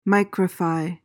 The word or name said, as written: micrify